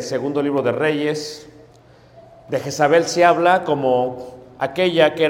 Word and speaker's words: Jezabel: En la Biblia se va a pronunciar en Tiatira el nombre Jezabel o Yeshabel.